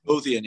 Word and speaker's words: Lothian: Lothian, yeah, yeah.